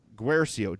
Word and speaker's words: Guercio: Ryan, Guercio. Let me know if I said that right, Ryan. I like to get last names right. – Ucio? – Guercio. G-U-E-R-C-I-O.